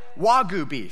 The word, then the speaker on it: Wagyu: Mangu beef? Wagyu beef.